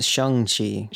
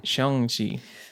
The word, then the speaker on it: Shang-Chi: It's pronounced Shang-Chi. – Shang-Chi. Because it sounds similar to Sean.